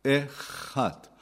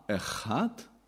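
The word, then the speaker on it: Echad (אחד): Ata, Echad. Das CH wird im Hebräischen immer, wie im Deutschen, das CH bei Dach oder Nacht ausgesprochen. Ja? Nicht Ch, sondern Ch. Ata, Echad.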